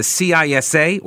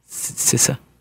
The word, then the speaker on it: CISA: This is, you're going to hear me refer to it as the CISA or CISA or CISA. CISA. Or maybe it's a hard C, like a ch, like a CISA.